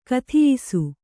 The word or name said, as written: ಕುದಿಯಿಸು